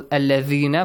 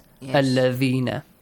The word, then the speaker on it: الَّذِينَ: For example, الَّذِينَ, of course, you should be able to pronounce it correctly, the letter ذَال, yes? الَّذِينَ.